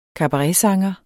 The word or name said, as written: kabaretsanger